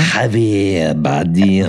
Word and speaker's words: Javier Bardem: I can't remember, how do you pronounce his first name? – Javier Bardem. – There you go, done like a true native.